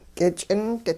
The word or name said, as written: k'ich'int